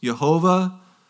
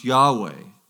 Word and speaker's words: Yahweh: Uh, the closest we can uh, make out in light of, of the missing vowels is that it's pronounced Yahweh. They used to think it was pronounced Jehovah, Jehovah, but now we know it's pronounced Yahweh.